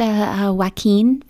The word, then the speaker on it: Joaquin: Yeah, that's not how you say his name, Chloe. – Yeah, it is. Joaquin Phoenix. That's his name. – No, that's how it's spelled, all right? Yeah, it's pronounced Joaquin Phoenix.